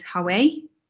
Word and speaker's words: Haway: So a couple I thought of is, one of the ones I always say is Hawaii. Like it's spelled H-A-W-A-Y, like Hawaii.